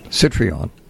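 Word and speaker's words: Citroën: Well, real quick, speaking of foreign cars, a listener says, I was in Rome last week and came across the Citroen. What? I can't pronounce it. – Citroen. – Yes.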